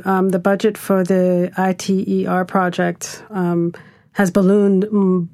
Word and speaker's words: ITER: I think the cost for ITER, we pronounce it ITER, ITER, is stabilizing.